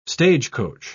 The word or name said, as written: stagecoach